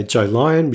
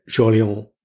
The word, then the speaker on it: Jolyon: Some people say Joe Lyon, which is not right, but Jolyon Wag, my favorite.